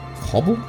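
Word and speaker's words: Cobel: She is the CEO of the company called Harmony Cobble. That's how you say it.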